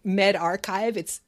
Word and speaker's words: medRxiv: It hasn't been, as you say, peer reviewed, but it's on what's called a preprint server, which is called MedArchive. It's spelled MedRxiv, that does screen its research before it's posted, even though they don't do a formal peer review.